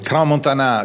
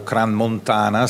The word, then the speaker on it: Crans-Montana: Crans-Montana si trova in una zona di lingua francese, generalmente nella dizione francese le parole sono accentate sull'ultima sillaba. Certo, noi possiamo accentarlo Crans-Montana se riteniamo di farlo, ma non è che questa è la pronuncia giusta. La pronuncia giusta è quella dei locali di solito.